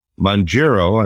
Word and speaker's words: Mounjaro: Libre 3, and then the new Lilly drug, which I believe is pronounced Mounjaro, I may be incorrect.